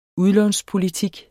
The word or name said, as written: udlånspolitik